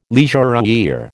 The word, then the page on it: leisurewear: American English